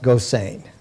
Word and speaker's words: Gosseyn: The hero, the protagonist of The World of Null A, has a Dutch name, Gilbert, and it's spelled G-O-S-S-E-Y-N, and it's pronounced Gossein.